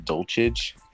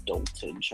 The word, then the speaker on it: Dulcich: And then for receiving, like, Greg Dulcich, how do you say it? Dulcich? – Yeah, it's like, I keep forgetting how to say his name. I'm going to say it's Dulcich, I'm pretty sure.